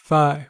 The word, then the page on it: phi: American English